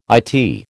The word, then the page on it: IT: American English